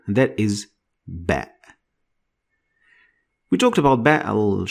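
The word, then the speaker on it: Ba (ب): Ba is pronounced like the B sound in English, like when you use it in the word bat. So bat, same pronunciation as ba.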